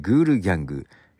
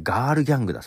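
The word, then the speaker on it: Girl Gang: えー、グールギャング、ルード・アウェイキング。はい。読み方は違いましたね。ガールギャングだそうです。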